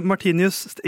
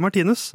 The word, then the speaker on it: Martinius: Uh, Martinius. Ikke Martinus.